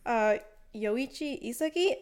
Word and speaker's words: Yoichi Isagi: Okay, so Blue Lock is about our main character, Yoichi Isagi. Am I saying that right? – Yeah.